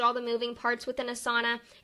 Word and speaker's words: Asana: I'm sorry that I mispronunciated. So yes, Asana. It's Asana. It's all one word.